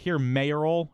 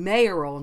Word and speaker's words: mayoral: Okay, first I'm going to say pronunciation-wise, it technically, usually, the original things was it's electoral, not electoral, and mayoral, not mayoral. That's more recent, the stress on the or part.